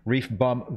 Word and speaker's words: ReefBum: Help Reef Brum, can't even pronounce my own name, Reef Bum Grill.